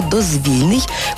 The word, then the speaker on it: дозвільний: Отже, говоримо «дозвільний», коли йдеться про відпочинок і вільний час. Дозвільні розмови, дозвільні ігри. У цьому випадку прикметник утворений від слова «дозвілля». А якщо прикметник утворений від слова «дозвіл», то наголос інший – «дозвільний».